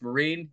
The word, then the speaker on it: Myrehn: M-Y-R-E-H-N is the last name, and somehow it's pronounced Marine.